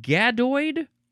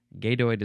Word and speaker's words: gadoid: It's gadoid or gadoid. G-A-D-O-I-D.